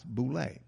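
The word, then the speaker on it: Boulé: They formed the BULE, an acronym for Sigma Phi Pi, and it's pronounced BULE, was formed to bring together a select group of educated American African men and women.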